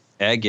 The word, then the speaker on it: Eigg: Eigg, yeah.